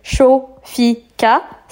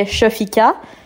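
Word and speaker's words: Shophika: Donc euh, mon prénom c'est Shofika, ça fait Shofika.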